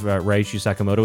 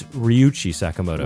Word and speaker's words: Ryuichi Sakamoto: She also said that my pronunciation of Ryuichi Sakamoto was wrong, which she's right. It's Ryuichi Sakamoto.